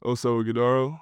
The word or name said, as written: Oso Ighodaro